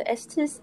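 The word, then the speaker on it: Estés: So this book talks about, I've not read that book, but Dr. Estes, if I'm pronouncing that right. – Yes.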